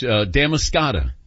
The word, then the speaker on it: Damariscotta: They call them the Napa Valley of Oysters, this Damariscotta area of Maine. And they say they're nutrient-rich, huge, and briny, just like you. – Yes, Damascotta is the correct pronunciation.